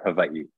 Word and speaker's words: Hawaii: And, when I'm in the right group too, I'll say Hawaii or Hawaii, because that's Hawaii. How it's pronounced there too.